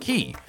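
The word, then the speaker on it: Cay: As I mentioned, I followed for a few years now, and I've noticed that at some point you switched the way you say Coco Key, pronouncing Key rather than K. I'm uh, familiar with the common Bahamian pronunciation as Key,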